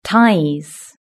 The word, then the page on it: ties: British English